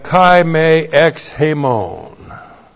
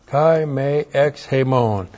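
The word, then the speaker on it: Kai Me Ex Hemon (καὶ μὴ ἐξ ἡμῶν): And then we have Kai Me Ex Hemon. Kai Me Ex. How about that? I got it all up there. K-A-I M long E E-K-S H long E-M-O-N Kai Me Ex Hemon.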